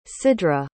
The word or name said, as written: sidra